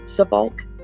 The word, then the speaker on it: Suffolk: According to the Soulfuck. This is not what I meant to say. Suffolk. How do you say that? Like, is it Suffolk? Not Soulfuck. It's close.